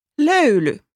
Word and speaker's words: löyly: Ö sounds little like the e in the and the o in word. Can you say it once more? – Löyly.